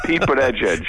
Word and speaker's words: Pete Buttigieg: But tell me how to pronounce that again. – Pete Buttigieg. – Buttigieg, okay.